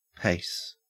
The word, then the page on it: pace: Australian English